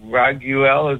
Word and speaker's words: Raguel: and then you spoke about Raguel, is that how you say it? – Uh-huh. – How do you spell that? – It's R-A-G-U-E-L.